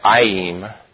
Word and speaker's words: ayim: is that in the Hebrew language there's an ending that they put at the end of certain words that's pronounced ayim, that means it's an ending that means two of something.